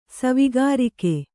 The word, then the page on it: ಸವಿಗಾರಿಕೆ: savigārike